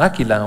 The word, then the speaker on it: Aquila: Encontré que la palabra griega Aquila tiene un acento en la A, como Áquila, o sea, como si fuera águila, pues.